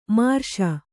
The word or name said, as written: ಮಾರ್ಷ